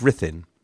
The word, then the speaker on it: Ruthin: So, once at Northampton, Buckingham began to prepare with his commanders, Shrewsbury, Egremont and Edmund Grey of Rhythin. We've heard of the Greys of Rhythin before, which I have relentlessly mispronounced as Ruthin. Stupidly, of course, on account of the fact this is the way it's spelled.